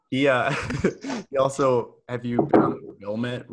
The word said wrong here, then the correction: Willamette: River? Wilmette? That rings a bell. – The Willamette. – Willamette. Oh man, I butchered that.